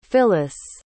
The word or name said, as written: phyliss